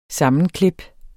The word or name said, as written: sammenklip